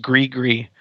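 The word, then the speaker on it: Gris-Gris: Let's listen to, there's an album called, I think it's pronounced Gree Gree, by Dr. John, back in the 60s.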